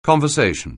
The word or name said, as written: conversation